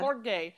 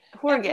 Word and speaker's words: Jorge: And like, how'd you say his name again? Hort. Uh, Jorge. – Jorge.